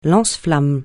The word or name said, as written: lance-flammes